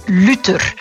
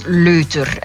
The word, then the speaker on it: Luther: Vandaag gaan we het hebben over het woordje Luther. – Luther. – Soms ook uitgesproken als leuter. Hè? Luther, leuter.